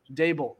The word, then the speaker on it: Daboll: Uh, da- uh, Dabal? – Dable. – Do I even have it spelled right? I'm no, you do. do it's pronounced show, Dable. Dable.